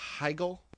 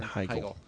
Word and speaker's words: Heigl: Well, Knocked Up, for those of you who don't know, because you're living under a rock, stars Seth Rogen and Katherine Heigl. Is that how you pronounce yes. – it? – Yes. Emmy Award winning. – Yeah, that's right. Emmy Award winning. – Katherine Heigl.